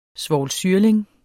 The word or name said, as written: svovlsyrling